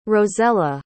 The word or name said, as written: rozella